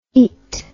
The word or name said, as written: aet